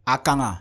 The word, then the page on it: akanggaa: Efik